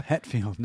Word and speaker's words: Hetfield: For those who don't know who Hatfield is, the singer for Metallica. – Hetfield, not Hatfield. There's no McCoys fighting him. – It's Hetfield. – Spell it. – H-E-T, Het.